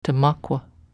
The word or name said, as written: tmakw